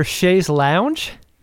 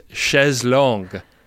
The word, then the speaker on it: chaise longue: Was that a word for chaise lounge? – Yeah, it's a French term, and it's pronounced chaise longue.